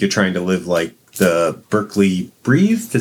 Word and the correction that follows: Breathed: Is that how it's pronounced? Bloom County's author? I've never quite known. – I think it's Breathed. – Breathed? Okay. Berkeley Breathed.